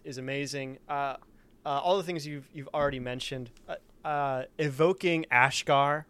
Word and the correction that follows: Ashvar: right? Having him painted. – Ashvar. – Sorry, I couldn't. Yep, there you go. Pronunciation.